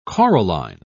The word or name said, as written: coralline